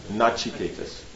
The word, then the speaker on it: Nachiketas: Like you have Katha Upanishad, okay, Natikechas, is that how you say it? Natikechas. Nachiketas, yeah.